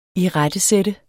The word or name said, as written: IRETTESAT